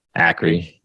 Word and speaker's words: Akre: Charles Akre? Is it Aker or Ackery? Ackery. So Ackery, uh,